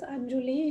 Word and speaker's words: Anjuli: Äh, ich nehme es äh, nicht persönlich, wenn man das nicht richtig aussprechen kann, aber so wie ich das ausspreche, ist das Anjuli.